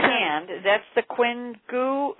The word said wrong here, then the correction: Qian Gu: How do you pronounce that? – It's Qian Gu points.